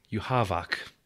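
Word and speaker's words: Yhwach: So apparently the last bad guy in Bleach is pronounced Yuhavok. Comes from Anglo-German.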